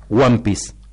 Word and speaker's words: One Piece: Es one, de one, de uno. – Piece, se piece. Escribe. – One piece. Uh-huh. Así se pronuncia.